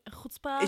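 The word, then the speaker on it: Chuzpe: Chutzpah? – Ich finde ich, ich find ja, find ja sowieso schon die Aussprache von Chutzpe relativ schwierig oder ungewohnt, weil man dieses Ch im, im Rachen ja.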